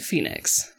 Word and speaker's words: Phenix: But it's spelled P H E N I X. Phoenix. But they pronounce it Phoenix.